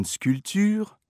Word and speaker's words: sculpture: To pronounce the U in sculpture, close your mouth and purse your lips. Make sure your tongue is at the front of your mouth, pressed against your lower teeth. If you let it shift to the back of your mouth, you will be pronouncing U as in vous.